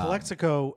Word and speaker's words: Calexico: Calexico.